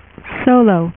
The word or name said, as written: Solo